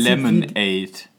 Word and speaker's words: Lemonaid: Also wird ja geschrieben Lemonaid. Also ausgesprochen im Prinzip. – Lemonade. – Genau, also ausgesprochen wie halt die normale Limonade, aber anders geschrieben.